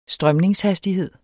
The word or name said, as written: strømningshastighed